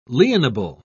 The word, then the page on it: lienable: British English